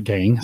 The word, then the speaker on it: gang: And I think like in the garage, I just need a single gain. So there's the gang. Sorry, I keep saying that wrong.